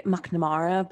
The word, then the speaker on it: McNamara: If you want to go like full rogue, you could say McNamara, but like nobody says that.